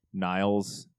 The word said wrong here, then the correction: Niels: Amelinks. – Sure. – Niels.